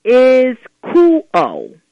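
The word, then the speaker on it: ischuo: The verb here is spelled I-S-C-H-U-O. I-S-C-H-U-O. And it's pronounced is cool-o.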